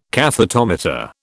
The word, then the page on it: cathetometer: British English